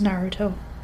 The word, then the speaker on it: Naruto: You keep pronouncing it wrong, we figured. – What? – It's Naruto.